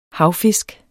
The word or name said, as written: havfisk